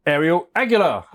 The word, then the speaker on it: Ariel Aguilar: Now, for this, I'm absolutely delighted to be joined by Ariel Aguilar.